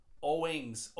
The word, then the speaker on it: Owings: O-W-I-N-G-S. Owings. Her name is Emily Owings.